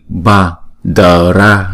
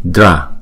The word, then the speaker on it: bhadra: Rồi chữ ra là chúng ta ghép lại là dra dra chứ không phải là đờ ra. Mani ba đờ ra là không đúng, dra, không?